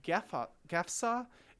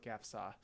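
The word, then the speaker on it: Gafsa: And they met Hilderic's forces at Kapspa, which is now called Gefsa, and is the capital of Tunisia. I may be completely mispronouncing that, but it's G-A-F-S-A, so in my English language I'm gonna call it Gefsa.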